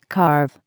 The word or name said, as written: carve